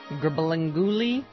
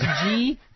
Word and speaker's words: Gurbanguly: I did not do the story because his name is Gribalanguly Birdigalagabunmakanup. – That's not how you pronounce it. – Okay. Anyone else want to try this? Gurbanguly. That's his first name. – Gribalanguly.